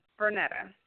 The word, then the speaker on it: Vernetta: Yes, Vernetta.